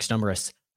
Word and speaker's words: Stumbris: You can follow me on the app formerly known as Twitter, at Kedrick Stumbris. Spelled how it sounds. Interestingly enough, people love to insert letters into weird places into the name. It is pronounced and spelled phonetically. Weird.